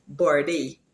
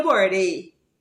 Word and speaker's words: birthday: There is an unvoiced TH, as in the word birthday. And we pronounce that the same rule for the voiced TH. So we just say birthday. When is your birthday?